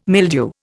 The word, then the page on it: mildew: Indian